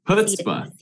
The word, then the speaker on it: chutzpah: Chutzpah. – C is silent, yeah. Chutzpah, okay.